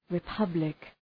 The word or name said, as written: republic